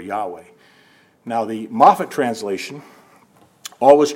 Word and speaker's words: Yahweh: Let's just say it's pronounced Yahweh for the purpose of this sermon.